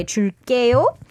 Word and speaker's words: 줄게요: But here you need to pronounce it a little tense. So say 줄게요.